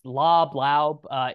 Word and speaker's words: Laube: Is it Laub? How do you say his name? – Yeah, I think it's Laub. It is spelled L-A-U-B-E.